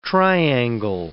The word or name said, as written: triangle